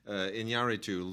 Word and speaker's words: Iñárritu: Iñárritu losing his the guy who wrote Babel with him. – Yeah, exactly. And I'm really impressed that you know how to pronounce Iñárritu.